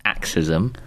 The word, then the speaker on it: AXYZM: it's weird, but it's pronounced axism.